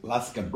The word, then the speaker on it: Luscombe: Peter, your surname, how do you pronounce it? – Luscombe. – Luscombe. What nationality is that? That's Cornish. It's from Cornwall.